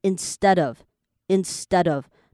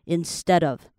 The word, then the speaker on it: instead of: 好，前面这个 i n s t 那边哈，你在念的时候 ints，你会发现它有一个 ts ts 的音，但它不是特别重的 ts，它就是一个 ts ts 轻轻的 ts，你就把它念成 instead of，然后最后面的那个 d 你可以稍微加力一下，就让 D 跟 OF 也是一个连音 instead of，那你把它慢动作播放的话就是 instead of，所以后面是 dove。